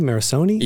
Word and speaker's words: Morricone: Morricone? – You got it right.